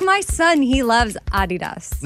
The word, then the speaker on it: Adidas: It's supposed to be Adidas, with a slight emphasis on the first syllable, not Adidas.